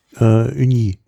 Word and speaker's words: Ugni: Oh, also UGNI geschrieben. Ich will es jetzt hier nicht komplett falsch. Wie spricht man das? UG? – UGNI? – Ja, Uni.